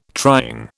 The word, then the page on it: trying: British English